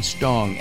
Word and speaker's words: Stong: I've been mispronouncing his name, and I apologize. It is Benjamin Stong, S-T-O-N-G.